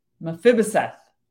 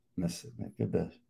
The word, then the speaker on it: Mephibosheth: So it's not chef. – No. – Okay. – I mean, not the way I've heard, but you know, all different accents and countries would pronounce it different. But it's the Mephibah part that I didn't get. It's a F. It's a F. Mephibosheth.